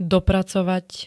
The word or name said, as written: dopracovať